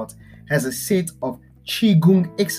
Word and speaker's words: Qigong: These exercises are collectively known as Qigong, pronounced Qigong, directly translates as energy work, but the idea is keeping the body and mind operating at an optimum.